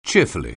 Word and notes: cheerfully: English

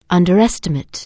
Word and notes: underestimate: anglais

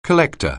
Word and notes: collector: anglais

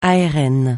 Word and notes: ARN: francais

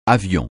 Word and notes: avion: francais

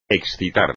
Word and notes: excitar: espagnol

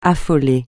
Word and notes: affolé: French

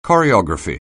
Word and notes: choreography: anglais

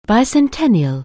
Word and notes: bicentennial: anglais

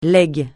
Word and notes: légitimité: francais

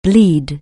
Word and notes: bleed: anglais

